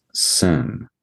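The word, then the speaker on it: sin (שׂ): And if you have a left accented shin character, it's pronounced sin with no H. And if it's a right accented shin character, it's pronounced shin, which is the redemptive thing, which, for instance, we see in the name Shem, for instance.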